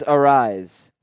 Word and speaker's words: Arraez: It comes out sounding like one syllable, but there is a subtle mouth movement where you pronounce the second vowel very slightly. – Okay, Luis Arraez.